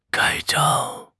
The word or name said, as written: 盖章